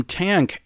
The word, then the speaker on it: Tangk: Their forthcoming album, Tank, and that's spelled T-A-N-G-K, drops on February 16th.